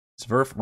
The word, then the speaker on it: Zverf: Zverf rhymes with Nerf.